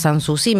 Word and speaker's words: Sanssouci: Se escribe Sanssouci, pero es Sanssouci, mirá.